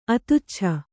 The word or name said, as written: ಅತುಚ್ಛ